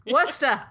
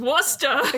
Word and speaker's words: Worcester: Wuster. It's Wuster. – Every city in Massachusetts, it's pronounced Wuster.